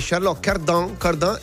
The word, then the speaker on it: Charlotte Cardin: E lei mi ha detto che Charlotte Cardin è l'esatta pronunciation.